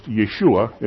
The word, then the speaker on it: Yeshua: Yahweh is the Greek equivalent of Joshua, which is pronounced Yeshua in Hebrew.